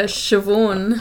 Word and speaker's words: Siobhan: Is that how you pronounce that? – Siobhan. – Yeah, Siobhan. – Oh, I didn't know that. – Yeah. – That BH sound. It's like in Welsh, a double D sound. Like if you've got two Ds together, it's actually a V. Like it makes a V sound.